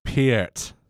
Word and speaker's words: Peart: Also, just before you get angry messages, you mispronounced his last name. It's Peart? Neil Peart. – Peart.